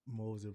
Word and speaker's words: Moser: Moser, appreciate that.